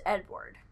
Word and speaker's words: Eadweard: I believe it is pronounced Edward. Here is how it is spelled. EADWEARD.